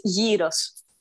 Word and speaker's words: gyros: So no, the correct one is gyros.